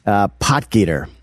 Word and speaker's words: Potgieter: What's his last name? – Potgieter. – Are you sure you're pronouncing this right? – I am. Okay. I am, yeah.